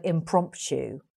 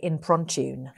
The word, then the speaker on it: impromptu: But my husband, Ned, he often has difficulty pronouncing certain words, like the word impromptu. He will say improntune. And the children and I are like, Ned, there's no nuh in the word.